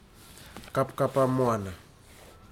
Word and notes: kapkapamoana: futuna aniwa

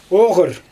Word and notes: oGyr: Xyzyl

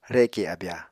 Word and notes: reke abya: Apatani

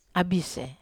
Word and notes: abis: Wamesa

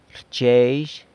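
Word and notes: rchyezhy: San Lucas Quiaviní Zapotec

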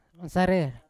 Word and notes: sarer: Yawa

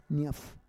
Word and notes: nyaf: Roon

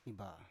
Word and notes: iba: Biak

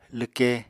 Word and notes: lwke: apatani

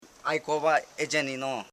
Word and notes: aikovaejenino: ai.ko.va.e.je.ni.no

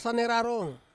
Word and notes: sane rarong: Ansus

